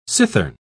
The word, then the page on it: cithern: British English